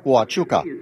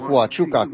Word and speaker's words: Huachuca: Am avut câteva serii acasă, apoi am fost la cel Ford Huachuca. Așa ați pronunța acum, nu înțeleg cum puteți pronunța Huachuca cu un H.